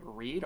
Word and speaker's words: Read: My Twitter is Jack Left on Reed, R-E-A-D. That's my name. It's supposed to be like Left on Red, but I don't think the pun works because my name isn't pronounced that way.